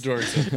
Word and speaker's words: Doerksen: In January, another Stanford engineer named Kyle Dorkson, I think I'm pronouncing that right. D-O-E-R-K-S-E-N. It's either Dorkson. – Dorkson. – It's Dorkson. – It's Dorkson. All right.